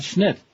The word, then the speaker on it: Schnitt: Todd Schnitt. S-C-H-N-I-T-T. Rhymes with spit.